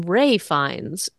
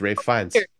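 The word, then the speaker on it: Ralph Fiennes: And then when you pronounce it with, when you say it with his last name, it comes out correct, but it's Rafe Fiennes.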